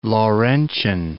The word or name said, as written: Laurentian Plateau